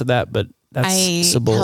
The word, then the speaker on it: Cibola: Cibola. – Oh my goodness, that is right. Wow. That's how you say it. Cibola. Yeah. Cibola is correct.